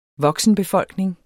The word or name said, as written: voksenbefolkning